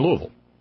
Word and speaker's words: Louisville: This one came in from our buddy Corey in Louisville. See, I said it right. Louisville. Louisville. You know, it's almost like you're just kind of grunting.